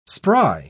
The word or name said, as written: spry